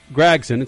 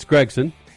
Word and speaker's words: Gragson: So, I'm not supposed to pronounce it Noah Gragson. It's Gregson.